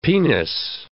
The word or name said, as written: penis